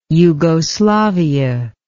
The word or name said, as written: yugoslavia